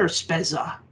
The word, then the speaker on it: Spezza: How do you say his name? Is it Spezza or Spezza? – I say Spezza.